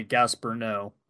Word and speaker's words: Gaspar Noé: This episode directed by Gaspar Noé.